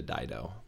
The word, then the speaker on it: Dido: I don't Dido. even know if that Dido. It's Dido. Yeah. It's Dido.